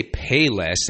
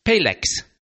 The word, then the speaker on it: Payless: And down here, the word Payless shoes rhymes with Rolex. So they don't say Payless, they say Paylex.